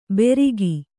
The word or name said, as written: ಬೆರಿಗಿ